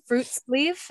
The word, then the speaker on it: friluftsliv: the word that it's fruit sleeve. Is that how you say it? – Uh, yeah, that's pretty close.